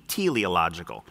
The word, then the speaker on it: teleological: It looks like you wouldn't know how to say it, but it's pronounced like T, teleological argument.